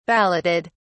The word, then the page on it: balloted: British English